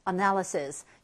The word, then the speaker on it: analysis: analysis. 여기 nal의 강세가 와서 analysis라고 발음이 되겠고요.